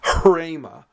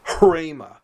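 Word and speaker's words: hrema (ῥῆμα): The word for word or utterance is pronounced hrema. You start with an H, but then you say R.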